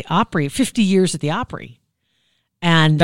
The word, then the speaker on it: Opry: It sounds like you're saying it wrong. – 50 years at the Opry. At the Oprah? No, at the Opry.